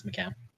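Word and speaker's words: Macoun: Is it McCown? – Yeah, it's McCown. – Terrible.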